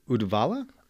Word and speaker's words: Uddevalla: So where I want to start the interview is in, I don't know how to pronounce it correctly, Uddevalla? – Uh-huh.